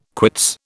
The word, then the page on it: quits: British English